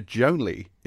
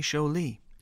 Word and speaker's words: Jolie: I say Jolie. – Jolie. – Jolie. Mm. Or Jolie. – Yeah, as in her mother was French, and so I'm using a vaguely French pronunciation.